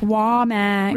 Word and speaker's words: Womack: Rachel Womack, WAMMACK, because I bet you everybody's like, how do you say your name? – Yep, Womack.